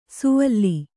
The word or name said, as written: ಸುವಲ್ಲಿ